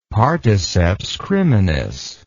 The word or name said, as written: particeps criminis